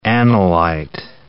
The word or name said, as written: analyte